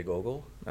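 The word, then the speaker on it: Gogul: Jay Gogol.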